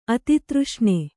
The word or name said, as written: ಅತಿತೃಷ್ಣೆ